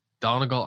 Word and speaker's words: Donegal: Donegal, Ireland. I assume I'm pronouncing that wrong. yeah. yeah. Um, it sounds right to me.